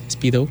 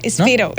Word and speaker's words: Spiro: Spiro, como la marca de trajes de baño, creo. – No, Spiro. – ¿No? Spiro. – S-P-I-R-O.